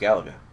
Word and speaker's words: Galaga: It is pronounced Galaga.